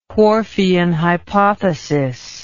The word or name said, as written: Whorfian hypothesis